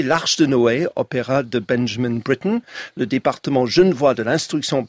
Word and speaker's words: Benjamin Britten: Benjamin Britten, britannique. Donc j'assume la prononciation anglophone.